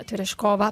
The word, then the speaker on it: Tereshkova: Tereshkova, perché ho chiesto a Google com'era la pronuncia esatta del cognome, non volevo fare figuracce come al mio solito. Tereshkova, sì. La devo dire così.